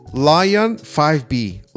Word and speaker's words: LAION: Pero empecemos primero con, ellos le llaman Lion 5B. Lion 5B, que se escribe L-A-I-O-N. – L -A -I Como león, pero con una A al inicio. Lion.